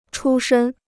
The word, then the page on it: 出身: chū shēn